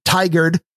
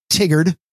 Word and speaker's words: Tigard: Dvorak, if you are listening, it is pronounced Tigard, not Tigard, Tigard, Oregon.